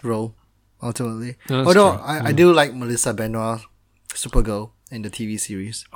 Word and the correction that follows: Benoist: I think she pronounced it Benoist, but...